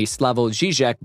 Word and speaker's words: Slavoj Žižek: The movie, though not at all based on the Slavoj Žižek book of the same name.